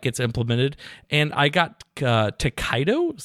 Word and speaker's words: Tokaido: T-O-K-A-I-D-O, Takedo.